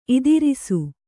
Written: ಇದಿರೀಸು

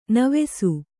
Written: ನೇವಸ

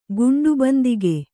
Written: ಗುಂಡುಬಂದಿಗೆ

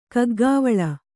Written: ಕೈಗಾವಲು

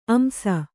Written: ಅಂಶ

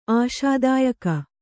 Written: ಆಶಾದಾಯಕಿ

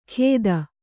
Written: ಖೇದ

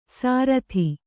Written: ಸಾರತಿ

